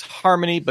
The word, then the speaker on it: Harmonai: Stability AI, the folks behind the Stable Diffusion text to image generator, are backing Harmonai. Yes, that's Harmonai, but spelled with AI instead of a Y, and somewhat confusing to read out loud. Harmonai just released Dance Diffusion at the end of September.